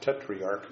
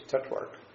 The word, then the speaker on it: tetrarch: Remember, it's not tetriarch, it's tetrarch. We don't have that third syllable in there.